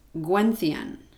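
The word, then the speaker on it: Gwenllian: It's G-W-E-N-L-L-I-A-N, but it's pronounced Gwenthion or Gwenthion, one of those two.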